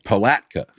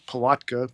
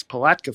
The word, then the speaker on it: Palatka: Palatka. – Yeah, I think I was saying Palatka, but it's Palatka, Florida. February 17th.